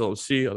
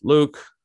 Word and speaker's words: Luc: My name is LOC, otherwise known as Luke. And by the way, my name is spelled L-U-C for people that don't know.